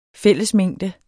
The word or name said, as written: fællesmængde